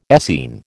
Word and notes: Essene: American English

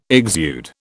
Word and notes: exude: British English

